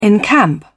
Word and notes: encamp: British English

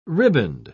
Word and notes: ribband: British English

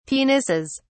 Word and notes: penises: British English

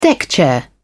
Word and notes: deckchair: British English

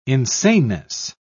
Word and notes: insaneness: British English

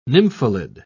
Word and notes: nymphalid: British English

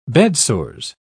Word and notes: bedsores: British English

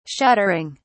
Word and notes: shuttering: British English

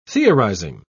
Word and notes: theorising: British English

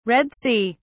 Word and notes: Red Sea: British English

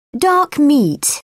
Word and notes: dark meat: British English

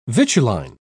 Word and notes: vituline: British English

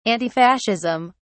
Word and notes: antifascism: British English

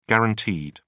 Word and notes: guaranteed: British English

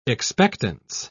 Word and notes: expectance: British English

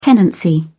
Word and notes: tenancy: British English

